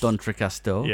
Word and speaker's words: D'Entrecasteaux: D'Entrecasteaux.